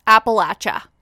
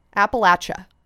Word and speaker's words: Appalachia: But I do know that listener said Appalachia. – That I said it wrong and it was throw an apple at ya. – Appalachia. – Appalachia.